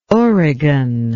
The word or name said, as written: origan